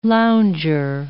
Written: lounger

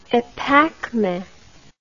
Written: epacme

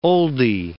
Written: oldie

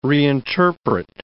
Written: reinterpret

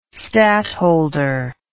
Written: stadtholder

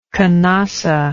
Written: canossa

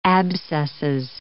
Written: abscesses

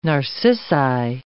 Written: narcissi

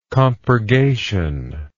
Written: compurgatory

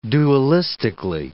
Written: dualistically

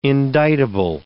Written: indictable

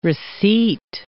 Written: receipt

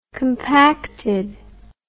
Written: compactedness

